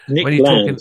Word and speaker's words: Nick Land: Nick Land.